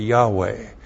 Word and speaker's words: Yahweh: If you pronounce that, you pronounce it Yahweh.